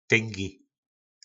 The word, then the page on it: tyngi: po nasimu